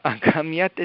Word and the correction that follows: अगम्यत: इति न तत्र हलन्तशब्दः अस्ति वा? – आम् अगम्यत. – हा अगम्यत इति अवश्यं वक्तव्यमस्ति.